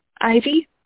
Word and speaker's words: Ivy: Ivy.